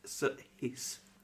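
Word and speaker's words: Saïss: And his name is pronounced, or Sace is pronounced, Cease.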